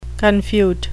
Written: confute

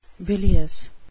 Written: bilious

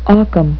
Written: ockhams razor

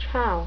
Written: ciao

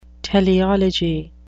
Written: teleology

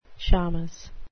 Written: shamus

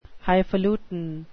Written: highfalutin

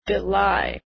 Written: belie

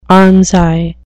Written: armscye

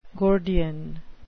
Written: gordian

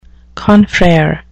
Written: confrere

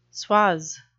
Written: suage

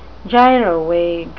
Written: gyrovague

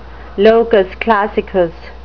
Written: locus classicus

